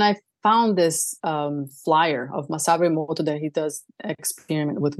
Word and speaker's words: Masaru Emoto: Masaru Emoto.